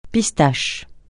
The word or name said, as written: pistache